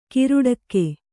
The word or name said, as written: ಕೇರಡಿಕೆ